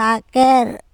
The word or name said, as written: akEr